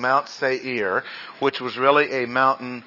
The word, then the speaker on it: Seir: So Esau, or Edom, lived in the hill country of, and this is not Seir. It is pronounced Seir. He lived in Seir, long A, Seir.